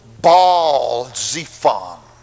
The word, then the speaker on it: Baal-Zephon: In Israel, they would pronounce it Baal, Baal-Zephon.